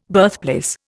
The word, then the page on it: birthplace: Indian